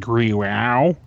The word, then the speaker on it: Grau: I believe it's pronounced Grau.